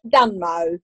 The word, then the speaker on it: Dunmow: It's called Switch Dunmo. Now, Dunmo is a town. It's really worth looking up because it's spelled D-U-N-M-O-W.